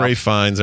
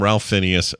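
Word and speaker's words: Ralph Fiennes: We know it's Ralph. Ray Fiennes, everybody. We know. – Ralph. – Yeah, but Ralph. We call him Ralph for fun. – Beloved. – It's spelled Ralph. – Beloved English patient, Ray Fiennes. – Yeah. – Ray Fiennes. But we're going to call him Ralph Phineas here and there.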